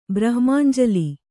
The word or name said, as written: ಬ್ರಹ್ಮಾಂಜಲಿ